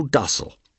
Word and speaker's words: docile: "There never was born a horse so docile." That last word pronounced, of course, in the American way, as you just heard, as if to rhyme with fossil, or that turdine melodic songbird, the throstle.